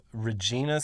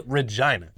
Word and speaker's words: Regina: People got real mad at us a couple weeks back because we pronounced Regina, Saskatchewan. Apparently it's Regina. – Pronounced Regina, which I refuse.